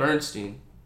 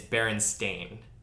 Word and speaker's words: Berenstain: It's Bernstein. – Actually, it's Berenstain. – What the fuck? – S-T-A-I-N. Yeah.